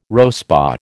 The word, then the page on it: RoSPA: American English